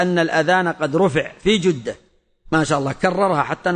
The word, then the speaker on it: جدة: يقول: كنا في الطائرة 7:30 يعني المغرب مساء فوق مدينة جدة، ونقول جدة بالضم في الجيم، هذا هو الصحيح. وأما جدة وجدة غلط، جدة هي أم الأب وأم الأم، وجدة من ضد القدم، والعوام ينطقون الجدة يقولون جدة جدة، والجدة هي ضد البلا ضد البالي القديم، وإنما هي جدة بضم الجيم، فمن نطقها بغير هذه الحركة فهو مخطئ.